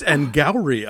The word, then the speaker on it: Gowrie: And it's Anne Gowrie, I learned. – Anne Gowrie. – Yeah, she said it rhymes with dowry. She says it rhymes with dowry or flowery.